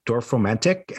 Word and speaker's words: Dorfromantik: Uh, it's a puzzle game that I think is pronounced Dorfromantik, and it's D O R F R O M A N T I K.